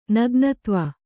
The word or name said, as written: ನಗ್ನತ್ವ